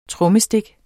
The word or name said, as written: trommestik